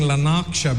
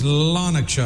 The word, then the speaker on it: Lanarkshire: We were saying Lanarkshire because the R is where just before the K. But they told us, no, it's Lanarkshire.